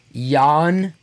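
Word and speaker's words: Ioan: His name looks like it's spelled Ion Gruffudd. – Okay. – But it's actually Jan Griffith. I don't know how they get the TH in there. It is Gruffudd. – And it's Griffith.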